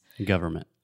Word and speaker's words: government: No, I'm saying you don't need to pronounce the in. Although we write the word government with an N, when we speak, we simply say government.